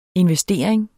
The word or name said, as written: investering